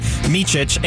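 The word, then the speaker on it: Micić: They sent away Trey Mann, Davis Bertans, and Valisice. I butchered that to hell. Sorry. Micic and some draft compensation.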